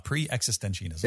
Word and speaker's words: pre-existentianism: He calls it pre-existentianism, if I'm pronouncing it right. – Yes. – Existentianism, yes. Pre-existentianism.